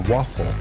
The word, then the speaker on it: Waphphle: It's spelled crazy. W-A-P-H-P-H-L-E. The Waffle.